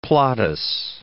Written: Plautus

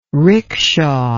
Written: rikisha

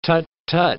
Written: tut-tut

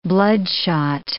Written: bloodshot

